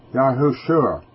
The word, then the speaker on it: Yahushua: And that's actually pronounced Yahushua. Y-A-H-U-S-H-U-A.